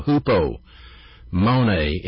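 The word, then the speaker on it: Hupomone (ὑπομονή): Hupomone, I'll spell it for you. H-U-P-O. Hupomone. M-O-N-E.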